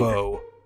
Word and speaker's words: Vough: For our listeners, his last name is spelled V-O-U-G-H. Steve. – Steve. – Vo.